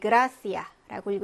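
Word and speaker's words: gracia: 네, 그라시아라고 읽으시면 되죠. 역시 기역 발음이 납니다.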